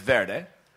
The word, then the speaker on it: Verde: In America, it's Varde. In Sweden, I can't pronounce it correctly, but There we go. In Sweden, I believe it's Verde. In American, it's Varde.